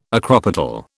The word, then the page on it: acropetal: British English